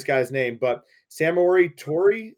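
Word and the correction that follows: Samori Touré: Samurai Tori, oh, Samari Samari Toure, yeah.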